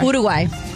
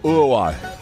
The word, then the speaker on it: Uruguay: I don't pronounce, I pronounce all vowels when I pronounce Uruguay, and it annoys my wife so much. I go, Uruguay. You know, like, I just don't even say anything. Right. It's just all U's and Y's, but they will be hosting matches in celebration of the 100th anniversary of the World Cup.